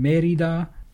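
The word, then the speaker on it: Mérida: There is a new 250 in Merida. And I, I totally did not pronounce that properly earlier. – Oh, that's okay. There's an accent on the E.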